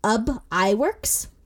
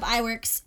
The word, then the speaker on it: Iwerks: So he hired Ub Iwerks, Iwerks like optometry? – Is spelled I-W-E-R-K-S. Almost like twerking, but with an I and not T.